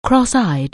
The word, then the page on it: cross-eyed: anglais